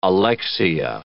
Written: alexia

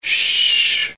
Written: sh

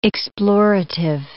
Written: explorative

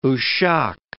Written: Oushak

